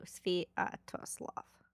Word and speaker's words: Sviatoslav: Sviatoslav.